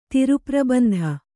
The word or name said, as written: ತಿರುಪ್ರಬಂಧ